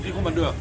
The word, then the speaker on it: Rekommandeur: Rekommandeur.